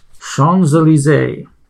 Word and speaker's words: Champs-Élysées: Champs-Élysées.